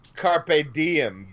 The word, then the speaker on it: Carpe Diem: Cart Dame Baby. – Oh, whatever. How the hell you pronounce that? – Okay, I believe it's Carpe Diem, baby, which means seize the day, baby.